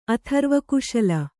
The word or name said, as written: ಅಥರ್ವಕುಶಲ